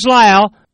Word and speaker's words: Lisle: Signs spelled S-I-G-N-S. Post Office Box 122. Town is Lyle. Spell that L-I-S-L-E.